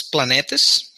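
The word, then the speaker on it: Planetes: I think it's Planetas, something like yeah. – that. – Yeah, it is. – Planetas? – Well, the way it's spelled is Planet ES.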